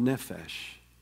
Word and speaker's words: nephesh: There's a wonderful word, it rhymes with refresh in the Hebrew, it's called nephesh.